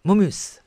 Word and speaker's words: Momus: Sì, Momus, alla francese, con l'accento sull'ultima sillaba e la S ben scandita e un po' sibilante.